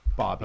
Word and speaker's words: Bobi: which is W-I-N-E, Wine. Yeah. – Bobi or Bobby? – It could be Bobai. – Bobai. No, he the driver was saying Bobby.